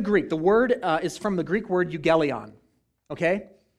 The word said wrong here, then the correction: euangelion: Euangelion, sorry. There's another syllable in there. Euangelion.